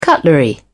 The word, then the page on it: cutlery: British English